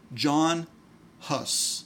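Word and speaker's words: Jan Hus: There was he had a friend by the name of John Huss. – Yeah. – And it's spelled Jan, but it's John.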